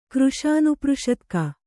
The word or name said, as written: ಕೃಶಾನುಪೃಷತ್ಕ